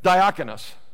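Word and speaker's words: Diakonos (διάκονος): That one has the Greek word of okanos. Okanos, am I saying that right? Yeah. I'm saying something like that. Diakonos, that's the word.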